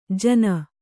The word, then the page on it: ಜಾನು: Kannada